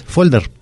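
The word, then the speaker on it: fólder: pero existe la castellanización de esta palabra, de la palabra folder, y es folder, pero con acento en la O.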